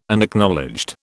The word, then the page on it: Unacknowledged: British English